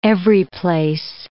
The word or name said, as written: everyplace